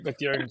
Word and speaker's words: Bethune: Pete, tell me if I'm saying your last name wrong. I feel like I've always said it that way, but how do you No, it's fine, Miles. Pete Bethune, so all good. – Bethune. Okay, great.